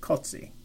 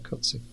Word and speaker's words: Cozzi: Well, I did notice that I've now heard him pronounce his own name, and it is, he does pronounce it Cozzi. So. – Yeah, Cozzi.